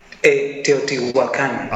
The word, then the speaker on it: Teotihuacan: Chichuacan, is that what it's called? – What's it called? What did you call it? – Teotihuacan.